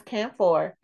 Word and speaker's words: camphor: I don't know, it's camphor. – It's camphor, people. Camphor. I love when Caribbeans always pronounce it because all of a sudden. – That's not, oh. – What? What were you gonna say? – I was gonna say alcanfor is how you say it in Spanish.